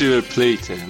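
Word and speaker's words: River Plate: ریور پلات و آرژانتین حرف بزنیم تلفظ درستش ریور پلیته من هی دارم میگم ریور پلیت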